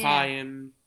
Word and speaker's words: Chaim: Chaim, Zami, we learned afterwards was the correct pronunciation.